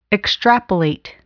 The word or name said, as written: extrapolate